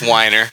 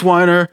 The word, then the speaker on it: Weiner: I'm sorry, it's Weiner. – It's pronounced Weiner. It's always been Weiner.